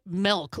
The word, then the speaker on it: milk: Because I say it milk, like M-E-L-K. That's my Wisconsin ease coming through. My Charlie Baron-ism, if you will. I pronounce milk as if it was spelled with an E.